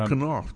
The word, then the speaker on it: Knopf: Oh, Knopf. Yeah. – I'm not sure. I think it's Knopf. Yeah. German for button.